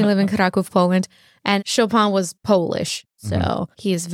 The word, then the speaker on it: Chopin: First thing first, I think the Poles really hate it when you have the French pronunciation of Chopin. – Yeah. – So the Poles would call him Chopin.